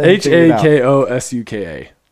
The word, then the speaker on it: Hakosuka: Let's let Hakosuka. – So say it again. – So the A definitely makes an A sound.